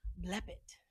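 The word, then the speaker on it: Blepet (βλέπετε): So this is the correct way to pronounce it. Blepet. B L E P E T E. Blepet.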